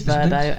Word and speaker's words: Vrbo: Spent the last few days working out how to say their latest sub-brand, which I think is Vrbo. – It is Vrbo, and they don't like it if you do it the other way, as anyone who listened to their call will tell you.